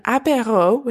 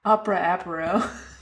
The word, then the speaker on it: apéro: So it's opera, and then apéro, which is A-P-E-R-O. – Opera apéro.